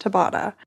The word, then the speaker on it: Tabata: Okay, so I just quickly Googled it and I think it's pronounced Tabata. So we're just going to go with Tabata.